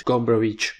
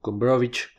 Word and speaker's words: Gombrowicz: ¿Quién es Gombrowicz o Gombrowicz, como se pronuncia usualmente en Latinoamérica?